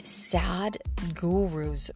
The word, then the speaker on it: Sadhguru: I took Sad Guru's advice, and, and he is it's it's spelled S A D H G U R U.